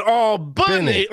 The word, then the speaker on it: Albany: Not Albany, but Albany. Like it's Benny. What? Nope. I know. I'm mocking. That's how it's said. That's how it's said. The South in Georgia is Albany, Georgia.